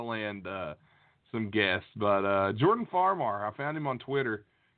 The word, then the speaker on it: Farmar: Farmar. – I don't know how it's pronounced. – Farmar.